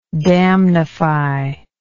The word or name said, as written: damnification